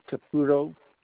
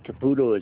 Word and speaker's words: Caputo: Yeah, my name is Anthony Caputo. You want me to spell that? – Yes, please. – Anthony regular, A-N-T-H-O-N-Y. And Caputo is C-A, P as in Paul, U, T as in Tom, O.